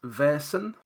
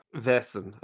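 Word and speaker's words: Vaesen: The pronunciation is Vesen. – Vesen, okay. – Imagine it's spelled V-A-I-R-S. That's the way it should be pronounced.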